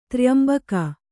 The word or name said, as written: ತ್ರ್ಯಂಬಕ